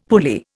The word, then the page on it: pulley: Indian